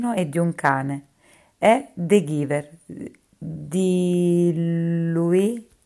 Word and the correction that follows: Lois: Lorry, Lois Lorry, scusate la pronuncia.